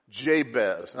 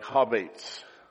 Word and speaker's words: Jabez: Now, that's how we say it in English. If you were a Hebrew scholar, you wouldn't say it like that. Actually, it'd be like Habates. You know, actually, it's Habates.